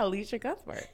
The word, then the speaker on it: Elisha Cuthbert: Al- Elisha Cuthbert? Is that her name? – yeah, Elisha Cuthbert.